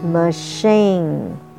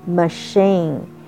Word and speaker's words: machine: Okay, and next word we're going to learn is machine. Machine. M A C H I N E. C H pronounced as sh. Machine.